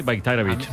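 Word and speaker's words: Bajraktarević: O sea, la J la convierte en una I, Bayraktarevich.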